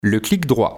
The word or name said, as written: le clic droit